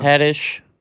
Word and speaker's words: Haddish: I don't know if you said her name right, but close enough, yeah. – Haddish. Is it Haddish? – Yeah, Nyjah Houston, Olympian, apparently.